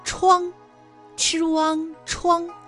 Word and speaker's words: 怆: 然而这个怆字啊，它还有其他的读音，比如一声 chuang ch u an 怆，表示悲痛凄怆的意思。